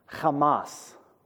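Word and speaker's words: Hamas (חמס): You read it from right to left. That's chet, and then mem, cha, m, and then that's a samek. And those little vowel points underneath are A's. So it's pronounced Hamas.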